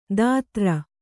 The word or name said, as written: ದಾತ್ರ